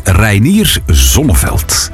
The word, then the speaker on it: Reinier Zonneveld: Wacht, je moet dat zo uitspreken. Hè? Reinier Zonneveld.